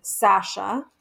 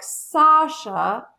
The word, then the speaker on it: Sasha: And as a matter of fact, I'm thinking my father pronounces my name Sasha. – Uh-huh. – And my mom pronounces it more like Sasha. And then some people say Sasha with like an O-ish sound.